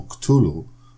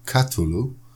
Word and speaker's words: Cthulhu: A druga sprawa, że samo Cthulhu ja osobiście wymawiam w taki sposób, w Stanach mówi się Cthulhu i zapewne w innych zakątkach świata jeszcze inaczej, choć tak naprawdę wszyscy robią to źle, ponieważ słowa tego nie jest w stanie wymówić człowiek.